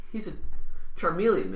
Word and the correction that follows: chameleon: man, or Carmeleon, I don't know how to say it. – Chameleon.